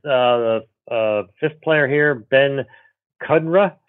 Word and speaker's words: Kudrna: It's pronounced Kudrna like Moderna. – Kudrna.